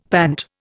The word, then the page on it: pant: Indian